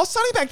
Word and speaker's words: Sunnybank: I found this little place in, I don't know if I'm pronouncing it right, but Sunnybre. – Oh, Sunnybank.